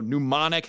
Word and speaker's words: mnemonic: mnemonic has a silent P or something like that.